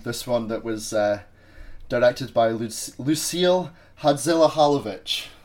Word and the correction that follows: Hadzihalilovic: Hadzihalilovic. That's it. Sorry. – You don't say the H's. You don't say H's in French.